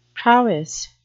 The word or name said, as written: prowess